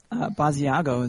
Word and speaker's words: Basiago: Basiago is how I would say it. – Ah, nice. Like the cheese. – Yeah, that's right. Like the cheese, but with a B at the beginning.